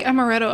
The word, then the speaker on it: amaretto: Which actually, I think amaretto. I'm trying to remember what all's in it. I know it's like an. It might be the amaretto. Actually, am I saying that right? – Yeah, you are.